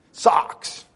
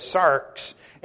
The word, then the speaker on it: sarx: The word in the Greek is sarx. And that's a very difficult word from a man from around to pronounce. It's going to end up sounding like my favorite baseball team. Sox. But it's sarx.